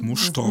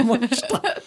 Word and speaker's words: Mostar: Aus Mostar. – Mostar. – Das ist das Balkan, die balkandeutsche Aussprache. – Trinken wir ein bisschen Mosch trinken, Mostar. – Mostar.